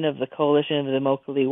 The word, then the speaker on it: Immokalee: in the town of Immokalee. It's pronounced like broccoli, Florida, which is the largest migrant worker community in Florida.